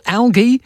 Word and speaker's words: algae: Martin says, hey, Phil, algae is pronounced with a hard G, not a soft.